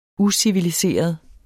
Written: uciviliseret

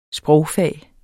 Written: sprogfag

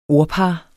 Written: ordpar

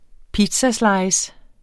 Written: pizzaslice

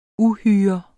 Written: uhyre

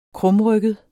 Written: krumrygget

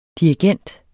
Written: DIRIGENTER

